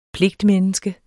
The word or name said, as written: pligtmenneske